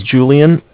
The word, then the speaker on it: Julian: Okay, my name is Julian. It's J-U-L-I-A-N. – Mm-hmm. – And I'm calling from Bank of Cardiff. It's spelled C-A-R-D as in dog, I-F-F as in Frank.